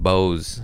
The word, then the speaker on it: Bose: So I bought some Bose noise cancellation headphones.